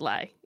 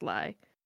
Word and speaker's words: Ely: And before I forget, my town on the map going back is named Lie, E-L-Y, just pronounced Lie, for Carlos's sake. So for our clan, E-L-Y.